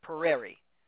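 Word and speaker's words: Peri: And there's a play in the 1600s by Pereri. And it's P-E-R-I, so in case you think I can't pronounce it.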